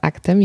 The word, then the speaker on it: ACT: Ale to, na czym zależało twórcom aktu, to żeby, stąd też tak wymawiamy to słowo, bo czasami widzę tam różne próby językowe, różnie ludzie wymawiają ACT, często ktoś nie wie i nie zna, ale zależało im na tym, żeby to akt kojarzyć z działaniem.